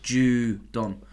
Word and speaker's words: Judon: Just in case you don't know how to pronounce Aguilar as well, it's pronounced Judon. – But in capitals, JOO-DON small. wide receiver Nelson Aguilar.